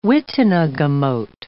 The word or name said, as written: witenagemot